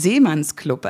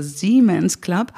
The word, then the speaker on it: Siemens Club: Das ist aber auf Englisch ausgesprochen, das heißt Seemanns Club, also Siemens Club.